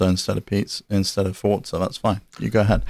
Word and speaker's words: Forza: Forza? – It is Forza, like pizza.